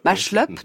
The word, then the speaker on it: Machlup: qui s'appelle Fritz Machlup, je ne sais absolument pas comment ça se prononce. – Machlup, d'accord.